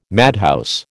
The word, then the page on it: madhouse: American English